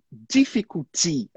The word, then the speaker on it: difficulty: Porque quando a gente está falando do adjetivo difícil, a gente fala difficult, né? This is uhum. difficult. – Isso é difícil. Quando a gente está falando do. Como é que fala noun mesmo? Substantivo, aí a gente muda a ênfase. Difficulty. A gente tem que forçar o final também. Entendeu?